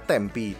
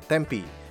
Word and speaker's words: Tempe: dari kota yang namanya Tempe Nyebutnya tempi, tapi tulisannya adalah tempe. Benar-benar tuh T-E-M-P-E, e, tempe, tapi bacanya tempi.